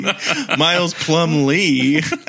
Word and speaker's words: Plumlee: Miles Plumlee.